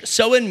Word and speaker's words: Samhain: You spell it S-A-M-H-A-I-N, but you pronounce it Samhain in Irish.